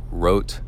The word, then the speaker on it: Roth: In the racing world, the biggest triathlon to go down lately is Roth, and I believe they pronounce it Rote. It's in Germany, but it's spelled R-O-T-H, and it's not an Ironman brand race.